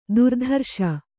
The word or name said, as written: ದುರ್ಧರುಷ